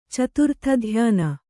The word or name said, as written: ಚತುರ್ಥಧ್ಯಾನ